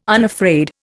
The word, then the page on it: unafraid: Indian